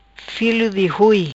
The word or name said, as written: Filo di Hui